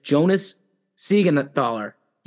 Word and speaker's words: Jonas Siegenthaler: Jonas Siegenthaler.